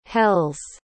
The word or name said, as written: hells